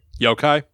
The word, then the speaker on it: yokai: And so some of those characters of those stories have as well kind of like been passed along, known as yokai, which I know you're going to talk about. And that was is that how you pronounce it? – Yokai. – Yokai, okay.